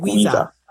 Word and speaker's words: Wiza: You kind of drag it out like Wiza.